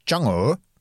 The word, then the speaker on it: Chang'e: Og den heter jo da, vi skriver det Chang'e 5, men jeg så en kineser si, nå må dere skjønne, dere får prøve å uttale det riktig, og det er Chang'e. Det er sånn de sier det,